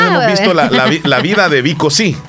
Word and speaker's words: Vico C: Entonces, la película de Vico Sí es la historia de él. Y se escribe Vico C. Así, Vico C. Y con V. – Vico Sí.